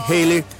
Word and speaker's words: Haley: Como su nombre, Haley, se pronunciaba parecido al famoso cometa, entonces lo dieron vuelta y quedó Bill Haley and His Comets.